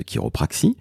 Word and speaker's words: chiropraxie: le site de l'Association française de chiropraxie. Chiropraxie, ça s'écrit comment Eh bien c'est très simple, C-H-I-R-O-P-R-A-X-I-E. Et oui, ça se prononce quelque part à l'américaine.